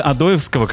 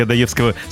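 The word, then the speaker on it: Одоевского: Смотрите, на улице Адоевского, как правильно говорить, да? – Да. – А мы привыкли Адоевского.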